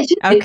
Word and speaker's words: Judit: Yeah, Judit.